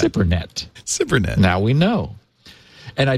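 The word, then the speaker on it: SIPRNet: I was calling it Cypernet, Cyprnet. And he sort of breaks it up phonetically, and he says it's pronounced SIP, S-I-P hyphen P-E-R hyphen N-E-T. – Cypernet.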